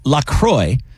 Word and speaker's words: La Croix: It's La Croix. – Should have a cheese name then. – Which rhymes with enjoy. It's not La Croix or La Croix.